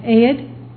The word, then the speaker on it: Ehud: E-H-U-D, how would you say that? Ehud? We'll say it's Ehud.